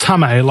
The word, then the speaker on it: Tame: I had Tame, it's pronounced Tame, like, you know, rubbing your tame, on the show twice.